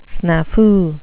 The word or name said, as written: snafu